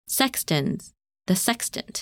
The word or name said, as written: Sextans